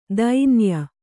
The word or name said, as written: ಧನಾಯೆ